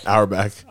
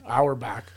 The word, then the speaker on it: Auerbach: And then the other one is Red, and I always pronounce his name wrong, so help me out here. Arbach? – Auerbach. – Auerbach.